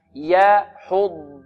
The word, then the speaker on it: يحض: ولا يحض على طعام المسكين، ولا يحض، ولا، هذه اللام مرققة، ولا، ولا، يحض، عندنا كلمة يحض كلها مرققة إلا الضاد، الضاد مفخمة فقط، والضاد هنا مشددة، ولا بد من نطقها ضادا وليست ضاء.